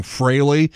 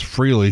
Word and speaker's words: Frehley: We were saying Frehley, and apparently it is Frehley.